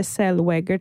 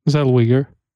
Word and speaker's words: Zellweger: Det var konstigt porträtterat av Renee Zellweger, tror jag man uttalar hennes efternamn. – Zellweger. – Ja, något sånt där.